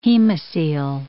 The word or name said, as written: hemocoel